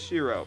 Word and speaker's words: Shiro: Is it Shiro? – It is Shiro.